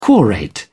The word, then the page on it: quorate: British English